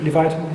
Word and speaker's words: Leviathan: Leviathan, yeah.